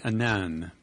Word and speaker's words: Annan: It's Kofi Annan, and I like it because Annan, they say, rhymes with cannon.